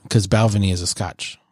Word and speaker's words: Balvenie: It's like Benvenue or something like that. I'm fucking butchering it. Double cask. – Balvenie? – What did I say?